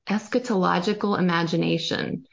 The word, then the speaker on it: eschatological imagination: So one of the terms you bring up, and I'm probably going to butcher it, but I'd love for you to speak on it for just a minute, is the eschatological imagination. – Yes. – Can you kind of leave, and then we'll talk about all the places you can be found, but I'd love to just kind of end on that because it's all part of it. – Absolutely. And you did a beautiful job pronouncing it.